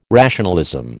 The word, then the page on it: rationalism: American English